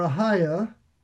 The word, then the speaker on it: Ahijah: Now, so this verse introduces Ahijah or Ahiah, you could pronounce it that way, a priest wearing an ephod.